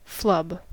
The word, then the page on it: flub: American English